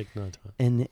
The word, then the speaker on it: Thich Nhat Hanh: Meaning like, so for me, I remember reading a Thich Nhat Hanh, yeah. – I pronounced that correctly. – Yeah, Thich Nhat Hanh.